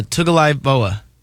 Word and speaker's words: Tagovailoa: Tagovailoa.